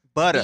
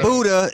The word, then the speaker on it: Budda: It's butter sauce, B-U-D-D-A. – Butter. – B-U-D-D-A. – There's a silent H at the end. – Not Buddha, it's butter. Butter sauce, not Buddha.